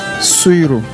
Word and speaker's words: Suyru: Suiru. – Suiru. – Oui. – J'aime bien prononcer. – S-U-Y-R-U.